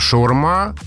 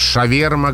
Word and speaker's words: шаурма: Где-то шаурма, примерно по-русски можно переложить так. Где-то шаверма, где-то шаварма, где-то шварма.